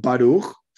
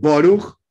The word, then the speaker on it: Baruch: algunos que dicen Baruch, sefaradim, otros dicen Boruch, ashkenazi tipo ruso, otros dicen Burich, más húngaro.